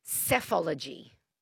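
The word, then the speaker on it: psephology: It's and it's pronounced cephology.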